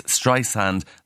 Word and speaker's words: Streisand: Anyway, she says, my name isn't spelt with a Z, it's Streisand, like sand on the beach.